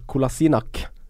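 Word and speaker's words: Kolašinac: Andre signeringa til Arsenal, Kolasinak. – Kolasinak. Den kunne du fint. Kolasinak. – Jeg har sett sånn video hvor han uttaler navnet sitt sjøl. Kolasinak. Ja. Kolasinac, sier jeg.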